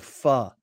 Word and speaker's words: pho: Is it pronounced pho or pho? – I always thought it was pho. But now they tell me it's pho.